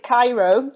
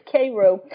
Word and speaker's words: Cairo: You know, people pronounce it Cairo, like the Egyptian version. But yeah, right. It's Cairo, the only spot where people pronounce it that way, Southern Illinois.